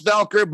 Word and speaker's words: Voelker: It's pronounced Voelker, but it's spelled V-O-E-L-K-E-R.